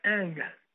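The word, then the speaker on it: Ng: Sí, mira, tengo entendido que se pronuncia Anga. King Anga. Anga.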